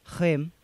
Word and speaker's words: Reims: Reims. – How do you spell that? – R-E-I-M, okay.